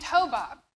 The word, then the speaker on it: Hobab: On the other hand, there's Moses's brother-in-law, this guy named Hobab. Neil told me I was pronouncing it correctly.